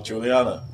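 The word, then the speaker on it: Julianna: Is it Julianne Pena? – Oh, Juliana.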